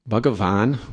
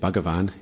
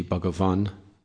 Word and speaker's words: Bhagavan: Bhagavan, when it's a long A at the end, Bhagavan, it's the object of the sentence. And if you want to say, O Lord, you would say Bhagavan. The last A would be short.